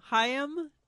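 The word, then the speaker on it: Haim: Oh, Chaim, is that how you say it? – Yes.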